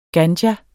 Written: ganja

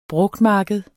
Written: brugtmarked

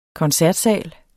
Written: koncertsal

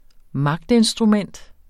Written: magtinstrument

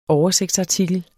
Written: oversigtsartikler

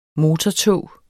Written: motortog

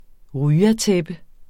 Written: ryatæppe

